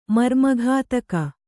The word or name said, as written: ಮರ್ಮಘಾತಕ